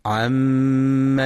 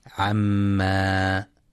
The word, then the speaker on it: عم: عم يتساءلون. ينتبه القارئ في كلمة عم لعدم إثبات ألف بعد الميم، فلا يقرأ عما، وينتبه لغنة الميم المشددة فهي أكمل ما تكون وقفا ووصلا.